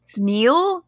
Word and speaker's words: Niall: Yeah, but they pronounce it Neil. I don't know, man. – Oh, have you been saying his name wrong the whole time? I thought it was Niall. – I've heard it both ways.